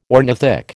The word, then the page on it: ornithic: American English